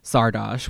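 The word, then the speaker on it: Szardos: But Googling Sardosh, which is how you would pronounce it if it's Hungarian, results only in X-Men results about Margali Sardosh.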